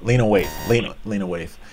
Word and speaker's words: Lena Waithe: Lisa Wraith. What's the? I'm not saying it right. Lena Waithe.